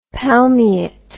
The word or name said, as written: palmiet